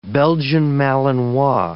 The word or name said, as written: Belgian Malinois